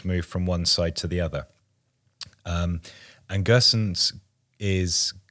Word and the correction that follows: Gosens: Gosens. – Gosens.